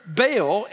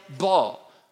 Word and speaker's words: Baal: I need to stop here and point out that there are some people who feel like the pronunciation of B-A-A-L is Baal, while others think it should be pronounced B-A-A-L, ball.